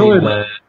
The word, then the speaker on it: Schroeder: Sviden, credo che si pronunci. – Schröder. – Come, scusami? – Schröder. – Schroeder, non Sweden.